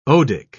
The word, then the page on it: odic: British English